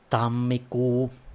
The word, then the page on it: Jänner: /ˈjɛnɐ/; /jenːnɐ/